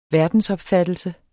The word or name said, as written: verdensopfattelse